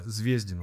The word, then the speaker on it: Звездин: Я неправильно делаю ударение. – Звездин он, конечно. – Звездин, а, Звездин. Звездин, да.